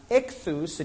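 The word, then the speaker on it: ichthys: The word for fish is pronounced ichthus, and each one of those letters is like an acrostic.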